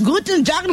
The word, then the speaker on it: Guten Tag: Guten Tag, lo dije bien. – Hola. Soy sí. – El saludo está muy buen pronunciado.